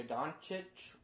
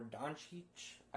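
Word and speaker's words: Dončić: Number three, the Atlanta Hawks select Luka Doncic or Doncic. I've heard it pronounced both ways.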